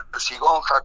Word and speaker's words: Ercigonja: Sí, sí, se escribe Ercigonja con J y con J, perdón. – Bien. – Y se pronuncia con I.